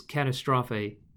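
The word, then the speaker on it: Katastrophe (καταστροφή): The Greek behind the word ruins is katastrophe. Did I say that right? – Katastrophe. – Katastrophe, which sounds... obviously you can hear the word catastrophe in that.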